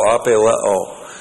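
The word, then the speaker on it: apeileō: The verb apeleo, to threaten, A-P-E-I-L-E, long O, apeleo.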